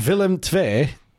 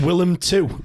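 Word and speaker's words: Willem II: The Willem Twee, I think is how you say it. sounds stupid saying that. I'd have called I thought they were Welsh. – I'd have called them Willem Two if I'd not looked that up.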